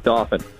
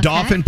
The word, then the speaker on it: Dauphin: I just was texting Nate to let you know that you have correctly nailed the pronunciation of Dauphin. You did an excellent job. – Dauphin, PA. It's both a county and a township, correct? – It is. It's Dauphin County, and then Dauphin is a little town just kind of north of Harrisburg. So there you go. – But I like the French pronunciation. It was very nice. It gives it a certain flair.